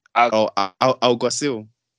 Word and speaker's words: Alguacil: Alguacil. – Alguacil.